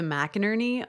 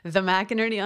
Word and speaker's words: McInerney: I am the McInerney on Twitter, which I probably should re-engineer, because McInerney is a horrible last name that has haunted me for a long time because no one can spell it or pronounce it.